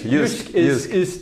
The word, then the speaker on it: Jysk: Jysk is the Danish pronunciation, and Jysk is a part of Denmark.